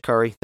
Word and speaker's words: Kaar: And just so you know, my name is pronounced Kar, K-A-A-R, just for next time.